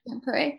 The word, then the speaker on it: Tampere: Tampere.